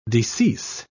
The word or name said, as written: decease